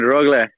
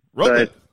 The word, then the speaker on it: Rögle: Yeah, it's always good, Rögle. – Rögle, okay. – Rögle. But it's different when we say it here in Sweden. It's Rögle.